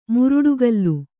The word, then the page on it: ಮುರುಡುಗಲ್ಲು: kannada